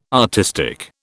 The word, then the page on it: artistic: British English